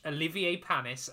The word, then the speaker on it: Olivier Panis: We are going to watch Olivier Panis, and I did make sure to say his name correctly.